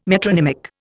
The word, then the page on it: metronymic: Indian